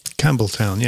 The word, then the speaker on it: Campbelltown: Campbelltown, yeah. And it has a P in it.